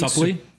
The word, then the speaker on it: Suplee: And then Ethan Suplee, is that how you pronounce his last name? Suplee.